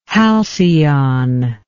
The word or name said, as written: halcion